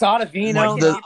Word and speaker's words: Ottavino: Ottavino. – The contract? – Ottavino. – It's Ottavino.